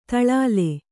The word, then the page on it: ದಲಾಲ: dalāla